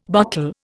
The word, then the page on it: botel: Indian